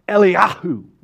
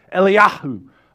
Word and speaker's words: Eliyahu: you got to pronounce Elijah's name that way. His Hebrew name is Eliyahu, right? Like you're trying to clear your throat. Seriously, that's how it sounds in Hebrew. Eliyahu.